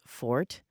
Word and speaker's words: forte: One word for me that falls into that category, I think, is fort, which most people pronounce forte. It originally was supposed to be pronounced just fort, but the dictionaries have changed now, and they say it is now acceptable to say forte, and so now I don't know what to say, because I used to say fort.